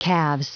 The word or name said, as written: calves